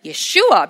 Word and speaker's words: Yeshua: Certainly in English it's Jesus, but as we were reminded last night, his name in the original pronunciation is Yeshua,